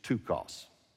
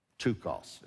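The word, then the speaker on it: ptochos: He used tukos. Tukos. It's spelled P-T, and the P is silent. O-C-H-O-S. Tukos, all right?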